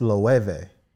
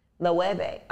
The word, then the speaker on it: Loewe: You know, I found it's Loewe. – Loewe. Oh, okay. Well, thank you. – The W. – It's a V? – It's a V. Loewe.